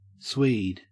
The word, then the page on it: swede: Australian English